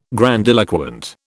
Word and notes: grandiloquent: British English

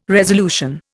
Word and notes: resolution: Indian

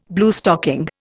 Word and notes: Bluestocking: Indian

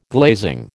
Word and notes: glazing: American English

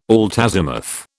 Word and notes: altazimuth: British English

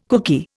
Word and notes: cookie: Indian

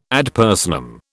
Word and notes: ad personam: British English

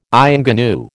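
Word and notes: ingénue: American English

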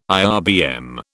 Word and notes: IRBM: British English

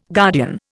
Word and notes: guardian: Indian

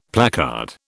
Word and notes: placard: British English

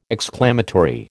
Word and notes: exclamatory: American English

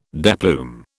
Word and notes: deplume: British English

